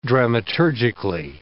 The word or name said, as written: dramaturgically